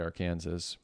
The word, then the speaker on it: Arkansas: Some people call it the Arkansas River and some call it the Arkansas. And interesting. You know what I thought when I read that? I didn't realize that that was actually a legit way to pronounce it.